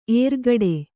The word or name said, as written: ಎರಗಾಡು